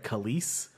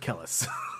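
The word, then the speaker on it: Kelis: I was amazed to learn that her name is like Kalise, because I just always pronounced it Kellis.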